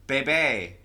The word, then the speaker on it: Beebe: All right, so on February 23rd, 1843, prominent Little Rock businessman Roswell, I don't know if you say his name Beebe or Beebe, it's B-E-E-B-E, Bebe, oh. Roswell Bebe, and Chester Ashley deeded 4 blocks to the young city of Little Rock for use of a cemetery.